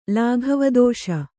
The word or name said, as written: ಲಾಘವದೋಷ